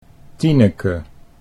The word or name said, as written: Tineke